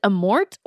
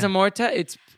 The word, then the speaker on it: Amorth: When Father Amorth, is it Amort or Amorth? – Well, it's Amorta. – Amorta. – It is Amorta. It's spelled TH, but I guess it's Amort.